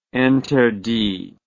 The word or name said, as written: interdit